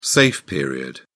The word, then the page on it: safe period: British English